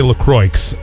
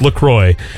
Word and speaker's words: LaCroix: And it's officially pronounced LaCroix. I used to say LaCroix a lot, but it's LaCroix.